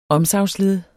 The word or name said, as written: omsagnsled